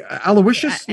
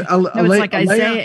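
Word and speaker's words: Isaias: Aloysius? No, it's Isaiah. It's with an I.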